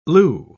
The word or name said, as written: leu